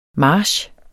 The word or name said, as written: march